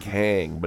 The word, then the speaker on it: Qeng: But we've got a version of Stark Tower in the background that has the word Kang, but it's spelled Q-E-N-G.